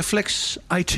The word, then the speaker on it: Flexit: Medeoprichter van FlexIt. Zeg ik dat goed? Of is het eh, Flex IT? Nee, het is Flexit. Eh, Flexit, toch echt waar.